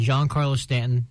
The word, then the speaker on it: Giancarlo Stanton: They have 2 of the best hitters in baseball, and Giancarlo Stanton,